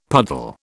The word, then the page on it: puddle: British English